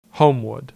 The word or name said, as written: Homewood